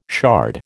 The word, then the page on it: shard: American English